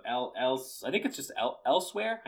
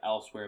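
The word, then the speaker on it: Elsweyr: It has a new expansion coming out called Moons of Elsewhere. I know it's not spelled like Elsewhere, but I think that's how it's pronounced, Elsewhere.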